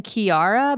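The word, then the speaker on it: Kiiara: He's featured on this song with, do you remember, I guess you say her name Kiara, but it's K-I-I-A-R-A.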